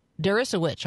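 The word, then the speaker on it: Deresiewicz: His name is, I don't know how to pronounce his last name, to be honest, William Derisiewicz. – Derisiewicz, I think. – Sure, that sounds good.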